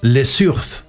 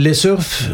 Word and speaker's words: Les Surf: Då toppas de spanska topplistorna av gruppen Les Surf. Alltså det ska nog uttalas franskt för att det är, de tog sig namnet där.